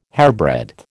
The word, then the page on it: hairbreadth: American English